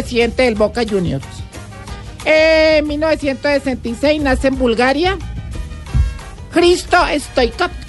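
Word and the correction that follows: Hristo Stoichkov: Risto Stoikov. – Bueno, ahí dice Cristo. – Bueno. – Pero dice Risto porque la H muda.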